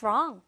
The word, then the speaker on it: wrong: What's wrong? 할때 우리 W는 발음은 나지 않고요.